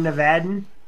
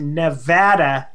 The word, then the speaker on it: Nevada: Is that Born Nevadan? I love it. – So much. – And if anything, it explains that we are pronounced Nevada, not Nevada.